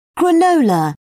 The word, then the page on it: granola: British English